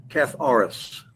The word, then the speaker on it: katharos: So the Greek word for pure is katharos. It's spelled K A T H A R O S, Katharos.